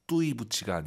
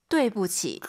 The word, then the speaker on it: 对不起: 아이유 사이에 오 그리고 유아이 사이에는 에 뚜이부치가 아니라 对不起.